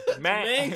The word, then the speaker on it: Mankey: It's not Mon. It's Man. Mankey. Man, not Mon. – It's Hanky, but with an M.